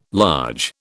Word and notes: large: British English

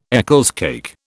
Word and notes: Eccles cake: British English

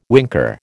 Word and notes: winker: American English

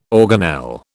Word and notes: organelle: British English

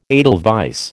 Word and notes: edelweiss: American English

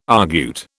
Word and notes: argute: British English

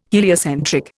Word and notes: heliocentric: Indian

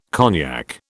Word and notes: cognac: British English

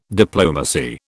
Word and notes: diplomacy: British English

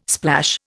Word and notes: splash: Indian

